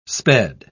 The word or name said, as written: sped